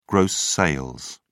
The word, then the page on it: gross sales: British English